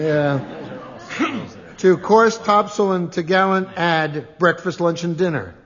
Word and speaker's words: topgallant: Course, topsail, and topgallant? Is that how you pronounce it? – Yeah. – T'gallant. – What? Spell that. – T-O-P-G-A-L-L-A-N-T.